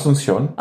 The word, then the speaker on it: Asunción: Wie spricht man das aus? Kannst du das aussprechen? Asunción. Asunción, okay.